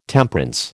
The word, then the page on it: Temperance: American English